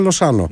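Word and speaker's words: Lozano: Το θέτα που λέει, θα λέει και η Ακριβώς, αν ήταν Αργεντινός, η προφορά θα ήταν Λοσάνο.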